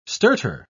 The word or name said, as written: stertor